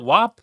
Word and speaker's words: WAP: WAP? – Yeah, no, you got it right.